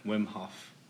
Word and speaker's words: Wim Hof: Wim Hof.